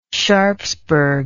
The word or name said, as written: sharpsburg